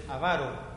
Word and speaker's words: avaro: Es avaro. – Amaro? – Avaro. – Avaro.